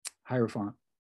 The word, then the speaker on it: Hierophant: Hierophant.